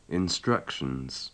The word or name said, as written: instructions